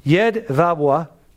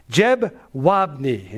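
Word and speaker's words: Jedwabne: Now in Polish, it's pronounced Jedwabwa. I can't speak Polish, but that's at least how I heard it said. But in English, it's Jedwabny.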